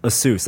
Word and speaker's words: Asus: The Asus ones, and it's pronounced Asus, like U-H.